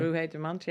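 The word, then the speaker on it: Ruahei Demant: Number 10 is Ruhe Dumont. I'm pronouncing him okay, am I? – Ruhe Dumont, you're doing a great job.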